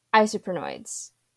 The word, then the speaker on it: isoprenoids: So you previously mentioned that you study isoprenoids. Did I say that right? – Yeah, that's right. That's perfect.